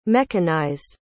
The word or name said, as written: mechanized